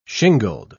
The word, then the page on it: shingled: British English